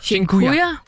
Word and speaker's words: Dziękuję: Og en af dem, som jeg har brugt rigtig meget, det er det, der hedder, undskyld min udtale, Dziękuję. – Dziękuję? – Dziękuję.